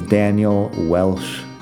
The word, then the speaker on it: Welsch: My name is Daniel Welsh. You gotta spell that correctly, W-E-L-S-C-H.